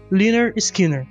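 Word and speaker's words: Lynyrd Skynyrd: Em terceiro lugar, eu coloco o Leonard Skinner, E o álbum deles, que é o primeiro, que também é chamado Pronounced Liner Skinner.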